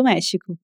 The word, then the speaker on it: México: Mas você conhece a palavra México, né? E provavelmente sabe que se escreve com X, mas tem o mesmo som do J do espanhol.